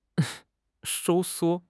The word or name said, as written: 收缩